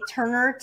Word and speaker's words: Turner: I always say that Lana is spelled with an H, L-A-H-N-A. Otherwise, backwards, it would spell anal. So it's just Lana, L-A-H-N-A, Turner, T-U-R-N-E-R.